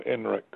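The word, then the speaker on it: INRIX: I-N like Nancy, R-I-X like X-ray. – Oh, INREX.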